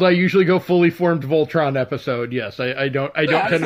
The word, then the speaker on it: Voltron: I said that right. It is Voltron.